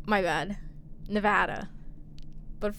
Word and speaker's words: Nevada: It's Nevada, not Nevada. – Nevada, not Nevada, yeah.